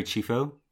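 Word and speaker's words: Chieffo: Mary Chifo, that's how you pronounce her surname.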